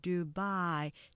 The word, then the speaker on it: Dubai: 우리가 보통 두바이 하고 삼음절로 표기하지만 음절 보시면 두 바이 이렇게 하면서 이음절로 되면서 뒤에 액센트 보이시죠? 액센트 조금 강조해서 Dubai.